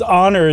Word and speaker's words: honor: No, but it depends on how much you emphasize the H in historic, because most people don't say historic, it's historic. – Right. – Whereas honor, it's like it starts with an O, like the H is silent almost.